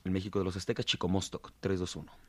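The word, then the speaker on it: Chicomoztoc: Chicomostoc, hasta ahora pronunciado Chicomostoc, fue el lugar de donde salieron las 7 tribus nahuatlacas.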